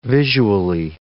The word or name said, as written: visually